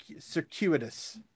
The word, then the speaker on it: circuitous: No, according to the Google pronunciation thing, circuitous.